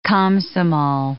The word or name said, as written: Komsomol